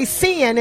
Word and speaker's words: SINN: I'm going to say Sin, and I want you to correct me when I bring you in, because I want to make sure I'm pronouncing it correctly. Sin, are you there, and can you hear us? – Yes, I am. Thank you very much, and that is correct.